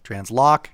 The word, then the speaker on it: Transloc: So we are partnered with a software company called Transloc, and I'll say that and spell it, but it's Transloc, capital T-R-A-N-S, capital L-O-C, Transloc. It looks like Transloc, it's not, it's Transloc. So when you get on the App Store, you want to search for Transloc, and the app will come up, you hit on that, and it's really specific to Johnson County.